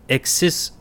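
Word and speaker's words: Ekşi Sözlük: Exisozluk, whatever. – May I? – Please. – The Turkish pronunciation is Exisozluk.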